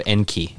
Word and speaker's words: Enki: The Lost Book of Enki. Is that how you say it? – Yep.